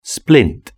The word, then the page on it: splint: British English